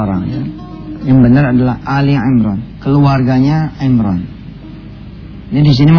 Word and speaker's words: Ali Imran: Biasanya suka bacanya Al-Imran. Padahal bukan Al-Imran tapi Ali Imran, ya.